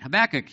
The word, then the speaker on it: Habakkuk: How do you pronounce it? Habakkuk, yeah.